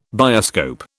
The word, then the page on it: bioscope: British English